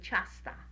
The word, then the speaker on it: ciasta: Well, all bakery goods, we would say ciasta. Do you know how to spell it? – I just want to get the pronunciation. – Ciasta. – Ciasta, yeah.